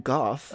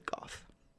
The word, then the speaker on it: Gogh: So yes, you pronounce it Gogh, not go? – It is either. I know in certain things that are produced in Europe, they pronounce it Gogh. Over here, we pretty much standardly pronounce it go. – Yeah, I don't know which is correct. Normal Americans say Vincent van Gogh. – Yes, but us pretentious assholes pronounce it Goff.